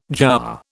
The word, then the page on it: jowar: British English